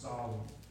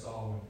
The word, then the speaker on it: Samhain: That's the Gaelic word, how it's pronounced, Samhain. It's spelled in English Samhain, but apparently it's Samhain in Gaelic.